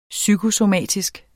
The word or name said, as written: psykosomatisk